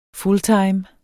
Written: fulltime

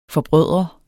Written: forbrødre

